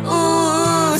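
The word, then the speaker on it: Oooooh: I asked him how to pronounce it because I think it's five O's and an H. So I believe it's ooh.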